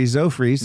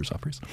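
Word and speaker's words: Zophres: So it's not Zo, it's Za. – It's Za, but I still call her. Well, I call her Mary Z. I don't call her Zofries.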